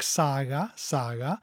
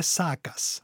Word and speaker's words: saga: So it's interesting also that, and we of course in Icelandic we say it with a soft G, if you like, saga, saga. But then this word has become a term about this genre of literature in a way, because this is of course also a Nordic heritage. And then we hear a lot talked about the sagas, yes, with a hard G.